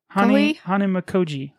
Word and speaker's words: Hanamikoji: I think it's just like Hanamikoa. – Hanamikoji. Hanamikoji, I think is how you say it.